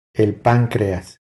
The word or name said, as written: El pancreas